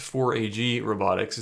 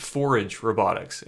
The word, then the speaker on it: 4AG Robotics: And another thing I noticed while I was researching this, it's not actually pronounced 4AG Robotics, it's pronounced Forage Robotics, as in forage mushrooms, which I also thought was pretty cool.